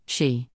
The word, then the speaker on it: Sidhe: It is from this that people came to believe that fairies lived under the ground, and they came to be called the Sidhe, pronounced she.